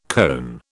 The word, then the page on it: cone: British English